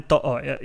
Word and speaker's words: To'o: Brian To'o is the official pronunciation of the Supercoach 365 podcast for Brian.